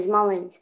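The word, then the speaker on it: Mullens: Way more passing yards than Nick Muslin. Muslins? – Mullins. – Mullins. I'm sorry, I can't pronounce it.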